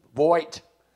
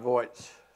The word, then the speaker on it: Voight: Is that how you pronounce his last name? Voight. Maybe that's right.